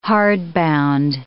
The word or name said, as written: hardbound